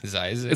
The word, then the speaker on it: Zzyzx: Spelt this town three is spelt ZZYZX. – Whoa, Zizix? – Zizix. It's like an I.